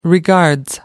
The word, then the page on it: regards: British English